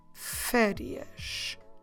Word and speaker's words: férias: we say férias.